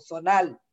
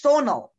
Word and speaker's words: Sonal: So, but it's like that's how Latinx people say it. And I guess like more Arab side people, Sonal. And it's Sonal. Say hormonal.